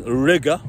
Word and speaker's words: Riga: And again, my Airbnb is $100 cheaper than the Airbnb back in Riga, which rhymes with nigga, and it's literally in the downtown area, guys.